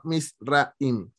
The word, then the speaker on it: Misraim: Es para que sigan a Silvana y a mí me pueden seguir en Twitter, Instagram, me pueden seguir como @misraim. MIZZRRAIM. Desde que dijiste aquella vez que mi nombre lo separó en sílabas, me se da a notar eso, que sí es cierto, lo separó en sílabas.